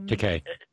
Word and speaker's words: Takei: I saw that apparently our friend Mr. Sulu, George Takei, I can never say his name right. – Takei.